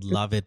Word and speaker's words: Lauvette: It's called Love It, but it's spelled kind of differently. I forgot how it's spelled. – Yeah. Differently. – Yeah, for all LAUVETTE.